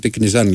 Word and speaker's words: Τικνιζιάν: Τικνιζιάν, γιατί δεν προφέρεται, λέει, είναι μένει με το Α. Τικνιζιάν, λοιπόν, να το λέμε.